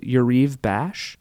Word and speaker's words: Yariv Bash: Yariv Bash? – You got it.